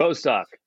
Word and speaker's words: Bostock: Bostock.